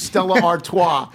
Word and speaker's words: Stella Artois: She correctly pronounces Stella Artois.